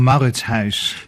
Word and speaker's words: Mauritshuis: Mais si on le prononçait à la néerlandaise, on le dirait comment? – Ça sonne comme Mauritshuis.